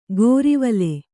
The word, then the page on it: ಗೋರಿವಲೆ: gōrivale